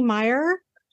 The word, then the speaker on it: Meier: Barry Meier, am I saying it properly? Meier? – Correct.